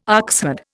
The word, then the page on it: oxherd: Indian